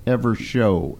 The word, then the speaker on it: Evershow: Is it Evershow or Evershaw? – Evershow, S-H-O-W.